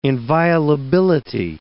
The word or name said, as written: inviolability